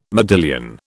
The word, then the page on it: modillion: British English